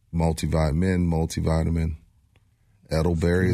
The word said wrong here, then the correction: elderberry: is that how you say it? elderberry. elderberry, yeah. Elderberry.